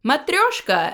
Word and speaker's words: Matryoshka: Hey everybody, today we're discussing Season 6, Episode 10, entitled Matryoshka, or Matryoshka, as you're actually supposed to pronounce it.